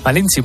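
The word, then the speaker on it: Malintzin: Malinchín, muy bien dicho, muy bien sí. Pronunciado, sí señor.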